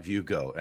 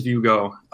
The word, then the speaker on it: VueGo: Well, tell us like a little bit about VueGo, and we're assuming it's VueGo, right? That's how we're pronouncing it? – Correct. It is VueGo.